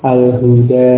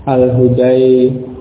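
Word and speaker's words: al-huda (الهدى): Diucapkan dengan alif, al-fata, al-huda, alif seperti al-aswa, ya, walaupun bentuknya ya, ya, bentuknya huruf ya ini. Jadi bukan huruf ya, dan tentunya karena bukan huruf ya, tentunya juga tidak diucapkan dengan ucapan huruf ya. Kalau itu huruf ya, diucapkan dengan huruf ya, akan menjadi al-fatai, al-hudai, tentunya akan semacam, akan menjadi seperti itu.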